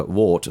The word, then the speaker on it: wort: It is now called either wort or wort, depending on where you're from. – It is always spelt W-O-R-T. – Yeah. – Some people pronounce it wort. Some people pronounce it or vert. Some people pronounce it wort because it's spelt wort. Some people try and pronounce some sort of ambiguous hybrid of the two to appease everyone.